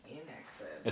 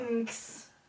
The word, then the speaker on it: INXS: In excess. – In excess. – Oh, I'm sorry. In excess. – It's very cool. It's cool. – It's a play on the... – Inks.